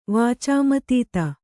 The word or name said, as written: ವಾಚಾಮತೀತ